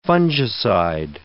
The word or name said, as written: fungicide